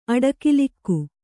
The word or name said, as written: ಅಡಿಗಲ್ಲಿಕ್ಕು